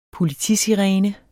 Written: politisirene

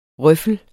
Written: røffel

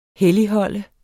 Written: helligholde

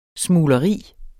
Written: smugleri

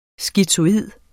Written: skizoid

